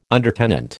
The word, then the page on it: undertenant: American English